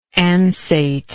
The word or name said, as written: ansate